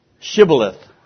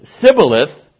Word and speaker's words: Shibboleth: And if he said no, then they would say to him, say Shibboleth. But if he said Sibboleth, for he could not pronounce it correctly, then they seized him and slew him at the fords of the Jordan, and there fell 42,000 of Ephraim.